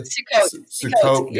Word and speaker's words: Sukkot: It says at the Sukkot gathering. Am I saying that correctly? – Sukkot gathering. Yeah. Okay.